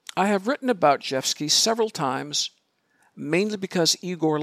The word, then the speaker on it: Rzewski: Speaking of the mispronunciation of names, I believe the composer's name is Zhevsky, Frederick Zhevsky, R-Z-E-W-S-K-I. And his Frederick is without a K on the end.